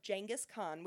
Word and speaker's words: Genghis Khan: He was talking to me about Genghis Khan, which is the correct way to say it.